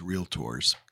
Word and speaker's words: realtors: When I spoke to this group of realtors, which I was instructed that it is realtors, the pronunciation. – You've done a very good job. You've said it right every time.